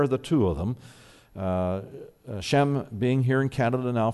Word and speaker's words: Sham: Her name is Sham, S-H-A-M, but it's pronounced S-H-E-M, Shem.